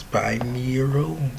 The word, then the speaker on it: binaural: Binaural? Binaural? It's binaural. Let me Google the pronunciation. Binaural. Binaural. I knew I was saying it wrong.